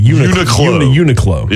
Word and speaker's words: Uniqlo: I'm being told by someone in my text I am saying it correctly. – Uniqlo.